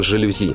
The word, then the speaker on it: жалюзи: «Солнце жарко привези, нам для окон жалюзи.